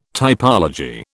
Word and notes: typology: British English

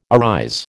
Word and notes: arise: American English

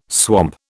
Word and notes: swamp: British English

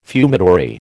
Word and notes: fumitory: American English

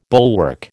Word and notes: bulwark: American English

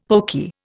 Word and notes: pokey: Indian

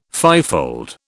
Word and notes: fivefold: British English

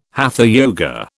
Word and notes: hatha yoga: British English

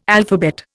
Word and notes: alphabet: Indian

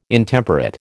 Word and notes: Intemperate: American English

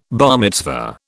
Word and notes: bar mitzvah: British English

